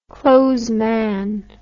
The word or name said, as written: clothesman